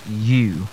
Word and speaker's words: ewe: Yes, and this word, E-W-E. Interestingly, this word people pronounce as Ewe. And I remember one of the senior, the WAC exams, saw the word, and a lot of students could not pronounce the word. Yeah, and the word is simply pronounced as U. Just the same way you pronounce your Y-O-U. Yes, U. That's the way it's pronounced. And the second word is the word Y-A-C-H-T.